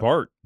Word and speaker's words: Barthes: So if you're quoting Roland Barthes, did I say his name right? Roland Barthes? – I think it's just Bart, but go ahead.